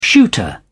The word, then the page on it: shooter: British English